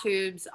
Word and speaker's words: Twoobs: Yeah, so we're at Twoobs on Instagram. It's T W O O B S. There's a silent W in there.